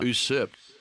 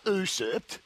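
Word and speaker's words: usurp: You get usurped. – You get usurped. It's not how you pronounce it. You know how you pronounce it, Craig? – Yeah. – Usurped. Yeah. Not usurped.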